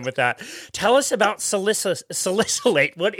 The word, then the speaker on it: salicylate: So I did want to ask you about salicylate. First of all, did I say that correctly? – You're good with all the big words.